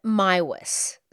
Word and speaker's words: Meiwes: His last name is spelled M-E-I-W-E-S. And in the documentaries I watched, they were all pronouncing it Maiwis.